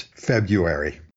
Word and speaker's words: February: Yeah, in fact, I would say the dominant pronunciation is February without the first R.